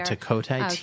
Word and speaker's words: Tacote: Tacote, T-A-C-O-T-E.